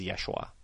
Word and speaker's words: Yeshua: The Aramaic pronunciation of Jesus' name is Yeshua, if that's what you're looking at.